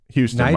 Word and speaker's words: Huston: Unless you're Nyjah Huston and have an indoor complex where you can Huston? – Houston, my bad. It's spelled Huston.